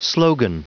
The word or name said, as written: slogan